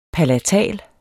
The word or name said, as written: palatal